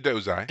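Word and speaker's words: Dosi: The Dossi culture was very interesting for the brief glimpse we got of it. – Oh, so you mean the Dozai. – Dozai. That's okay. Sorry. Mispronounced it.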